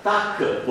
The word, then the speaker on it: Taq: Una polimerasi che in realtà si chiama, oggi ce ne sono diverse, ma fino a 4-5 anni fa c'era solo questa, TAC polimerasi, pronuncia TAC, si scrive T-A-Q.